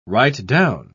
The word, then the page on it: right-down: British English